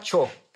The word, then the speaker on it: Choo: La señora Cho, para hacerlo fácil, C-H-O-O.